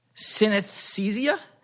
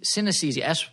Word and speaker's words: synesthesia: I know, and I thought I got really happy, but I think I just pronounced it wrong, and it's synesthesia, Synese- synesthesia, E S Y N E S T.